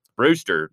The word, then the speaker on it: Brewster: I am visited here today by an individual all the way from Brewster, Nebraska, is the correct way to say it, yes.